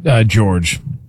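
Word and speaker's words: Georges: George. The S is silent. It's a weird one.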